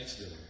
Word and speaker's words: Exeter: How do you say that? – Exeter.